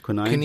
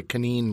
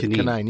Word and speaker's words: quinine: Tonic water, or Indian tonic water, as it was once known, is a carbonated soft drink which has quinine in it. Am I saying that right? – Quinine. – Okay. – Yeah, well, depends. Pakistanis say quinine, but quinine, yeah. – Okay, quinine. Thank you.